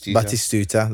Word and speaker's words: Batistuta: I would have said Batistua. Batistuta. – That's how you say his name.